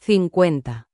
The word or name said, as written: 50